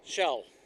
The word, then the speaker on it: Kjell: Shell. It's spelled K-J-E-L-L. Last name R-O-K-K-E, Roki.